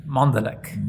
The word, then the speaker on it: Mondalek: Mondalek.